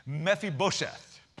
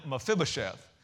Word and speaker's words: Mephibosheth: Now, the Jewish people pronounce this name Mephibosheth. I grew up calling him Mephibosheth. I can't say either one very well, but he's obviously Southern because Bo is right in the middle of his name.